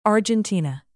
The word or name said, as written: argentina